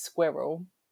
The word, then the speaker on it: squirrel: So obviously the correct way to say that word is squirrel. – Squirrel. – Squirrel. – Squirrel. – A what? – It's one syllable.